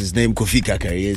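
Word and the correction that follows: Karikari: It's Kofi Kari Kari.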